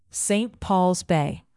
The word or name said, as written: St Pauls Bay